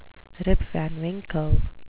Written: Rip Van Winkle

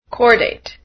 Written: cordate

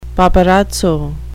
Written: paparazzo

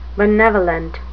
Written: benevolent

